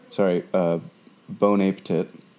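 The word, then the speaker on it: Bon Appétit: It's from Bon Appetit, Bon Appetit. – Yep, that's pronounced correctly.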